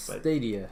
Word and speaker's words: Stadia: Or Stadia. I'm not exactly sure how it's pronounced. – Stadia. Like a stadium. – Oh. And then it's just, instead of the M, it's IA.